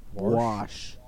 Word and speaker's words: wash: But also, how do you pronounce the word that's spelled W-A-S-H? How do you pronounce that? – Warsh. – I knew you would. Okay, some people pronounce it warsh. – It's mostly older people who pronounce it that way.